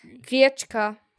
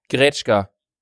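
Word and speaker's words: Gretschka (гречка): Ja, aber das spricht man nicht so aus. – Du meintest gerade, ja, wie, aber was das meinst ist du? das. – Gretschka. – Das heißt Gretschka. Gretschka. – Ohne I. Gretschka.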